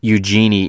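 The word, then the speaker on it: Eugénie: And I think it's Eugenie is actually how you say it. Le Sommer. All right, that is Leon's lineup for Benfica, playing in their typical 3-5-2.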